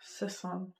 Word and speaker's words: Sesan: Sesson. – It's spelled S-E-S-A-N. – Sesson.